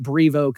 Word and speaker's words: Brevo: I guess that's how you pronounce it, Brevo. Now, I'm a Texas Longhorn, and we have this very large animal named Bevo. He doesn't have an R in his name. It's Bevo. So that's why I'm pronouncing it Brevo, because it looks like Bevo with an R in the middle.